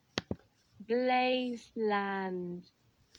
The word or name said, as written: Blazeland